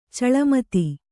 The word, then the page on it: ಛಲಮದ: chala mada